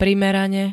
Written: primerane

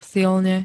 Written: silne

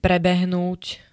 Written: prebehnúť

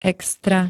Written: extra

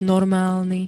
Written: normálny

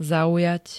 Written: zaujať

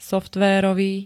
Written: softverovy

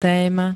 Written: téma

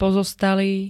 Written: pozostalý

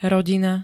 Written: rodina

